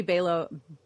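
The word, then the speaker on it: Balogh: First, I read Mary Belog. Rhymes with Kellogg. Mary Belog, even though I want to say Belog.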